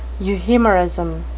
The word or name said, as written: euhemerism